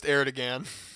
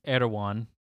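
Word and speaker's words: Erdoğan: It's not pronounced Erdogan. – It's Erdogan.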